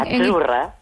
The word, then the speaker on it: Azzurra: Azzurra. – Azzurra. Mira, ¿ves? – Azzurra. Es que... Azzurra. Sí, como porque pizza, la Z no es la Z como es decimos en catalán tan sonora, no.